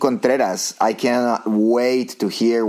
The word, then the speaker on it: Contreras: And Contreras. – Contreras. – That's pretty good.